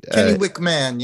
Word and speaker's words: Kennewick: The first question is, what was the date of Kennewick Man? Am I saying that right? – Kennewick Man, yeah.